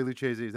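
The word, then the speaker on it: Lucchesi: But Perdomo, in relief of Joey Lucchese, did I pronounce it right? – Yeah.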